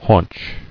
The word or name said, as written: Haunch